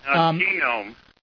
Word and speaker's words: GNOME: Yes, they pronounce the G. – No, he's in Rochester. – Yeah, he's in Putneyville. GNOME.